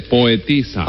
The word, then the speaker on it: poetisa: Y en este caso, este individuo lo que viene a ser es un poetizo. – No se dice poetizo, Tres Patines, se dice poetisa.